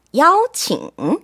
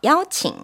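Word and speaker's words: 야오칭 (邀请): 네, 정답은 1번 야오칭. – 야오칭. – 네, 발음해 준 거죠? 야오칭.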